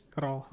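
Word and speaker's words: Ra (راء): Ra.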